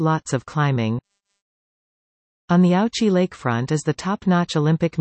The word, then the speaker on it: Ouchy: Lausanne has two tourist zones, the idyllic waterfront area of Ouchy, pronounced Ouchy, and the tangled and historic old town, directly uphill from the lake.